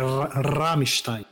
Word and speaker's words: Rammstein: O A é mais aberto, é Rammstein.